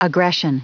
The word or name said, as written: aggression